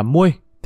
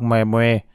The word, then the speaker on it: Moe: Lão này tên là Moe, đọc là Muôi. Thím nào đọc mè mè là sai rồi.